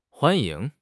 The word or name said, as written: huan1 ying2